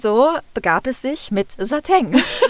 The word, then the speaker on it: Satin: Und dann steht da in der deutschen Aussprachedatenbank an erster Stelle die Aussprache Sateng. Wie Peng.